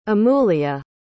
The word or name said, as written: amulya